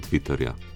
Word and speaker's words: Twitter: In Sploh, če bomo pa hoteli skanjati, bomo pa kako, če bomo citatno izgovarjali Twitter, ja, brez reja, z rejem, smešno bo ne in tudi nerazumljivo, tako da je bolje Twitter in Twitterja.